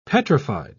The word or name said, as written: petrified